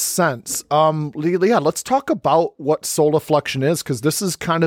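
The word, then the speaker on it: solifluction: So soliflexion, it's soliflexion. So soliflexion. – Yeah. – Right. So it's kind of a hard word to hear and know how to spell it.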